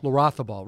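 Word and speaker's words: Larrazábal: Pablo Larrazabal, or I'm probably saying that wrong, Larrazabal, right, like Olazabal, yeah.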